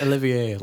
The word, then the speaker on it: Olivier: It's Olivier, like sort of like your French.